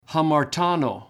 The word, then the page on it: hamartano: Greek